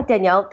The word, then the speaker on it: Danielle: Yeah, Danielle, that sounds about right.